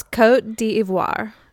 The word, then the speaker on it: Côte d'Ivoire: It's Côte d'Ivoire.